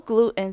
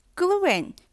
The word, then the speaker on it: gluten: cake flour 蛋糕粉又称为低筋面粉，英文是 soft flour, low gluten flour。 gluten 是面筋、麸质，就是小麦中的蛋白质成分，也可以念成 gluten。 gluten, gluten 两种发音都可以。